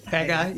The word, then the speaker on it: Haggai: Love the verse in, if I can say this right, it's a Haggai. – Haggai, yeah.